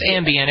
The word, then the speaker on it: Ambien: It's pronounced Ambien.